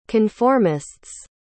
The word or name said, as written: conformists